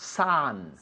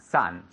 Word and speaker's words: son: Okay, escribe S-O-N, but it's pronounced san. – San. – San, san, como el sol, san.